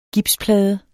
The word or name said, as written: gipsplade